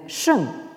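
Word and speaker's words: sheng (聖): Dans ce caractère qui se prononce en chinois moderne sheng, hein, au quatrième ton, et qui désigne donc le sage ou euh, la sagesse.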